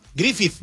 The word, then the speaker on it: Griffith: Cada vez que ponga la TH como the, como THE. – A ver, Griffith. ¿Cómo se dice, pues? – Griffith.